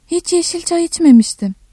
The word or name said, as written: iç-